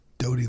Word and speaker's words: Doughty: How do you spell Dottie phonetically? Dottie. – D-O-U-G-H-T-Y.